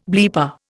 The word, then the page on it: bleeper: Indian